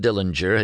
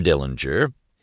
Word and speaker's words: Dillinger: Mr. Dillinger, he pronounced the name with a hard G in the German style,